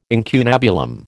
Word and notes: incunabulum: American English

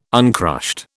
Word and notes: uncrushed: British English

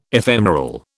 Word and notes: ephemeral: British English

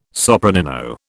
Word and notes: sopranino: British English

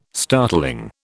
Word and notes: startling: British English

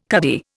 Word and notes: cuddy: Indian

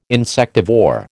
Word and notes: insectivore: American English

